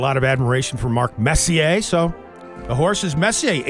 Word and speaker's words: Messier: One of the favorites, Messier. – Yeah. After Mark Messier? – Yeah. Not Messier, but it's spelled that way.